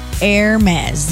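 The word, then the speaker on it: Hermès: Also, it's Hermes. Mm-hmm. French luxury house funded in 1837. Hermes. The H is silent.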